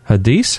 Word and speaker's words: Hadiths: Hadiths? How do you say that? – Hadiths.